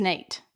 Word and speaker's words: Nayte: And I want to say, and I'm not really sure how to pronounce this, N-A-Y-T-E, is that Nate? – It's Nate, yes. – Okay, it's not Nate, it's not like ethnic or something, it's Nate. – No, it's Nate.